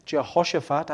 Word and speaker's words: Jehoshaphat: Jehoshaphat, I had to practice a lot so I wouldn't get it wrong.